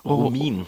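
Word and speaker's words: Romijn: Romine.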